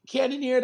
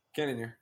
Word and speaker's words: Cannonier: Cannonier, that's how you say his name. Correct me if I'm saying it. – Cannonier, yeah.